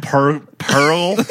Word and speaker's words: Poeltl: Jakob Poeltl. – That's how you say it. – Poeltl. – LTL. How do you do that? – Poeltl. You say Poeltl. People say Poeltl, I guess. That's the closest we can pronounce it. – That's how people say it. Yeah. I don't know. – It's Poeltl.